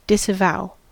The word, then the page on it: disavow: American English; IPA: /dɪsəˈvaʊ/